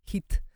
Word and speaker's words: HIIT: ¿A qué nos referimos cuando decimos hit? Hit eh, se escribe H I T de Tito y significa High Intensity Interval Training,